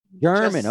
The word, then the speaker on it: German: And the guy doing the scoring was Jimmy Herman. Do I have that right? – German. – Okay. – It's just German.